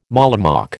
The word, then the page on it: mollymawk: American English